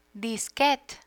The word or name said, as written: disquete